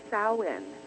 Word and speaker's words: Samhain: And it was to honor it's spelled Samhain, but it's called Samhain. It wasn't, you know, the definition of what it's spelled and the way that you pronounce it is completely different.